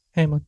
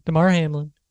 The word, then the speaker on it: Damar Hamlin: Hamlin. Damar Hamlin.